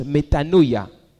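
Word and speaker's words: metanoia (μετάνοια): Repentez-vous vient d'un mot grec que l'on prononce « metanoia », qui veut simplement dire « changez votre mentalité ».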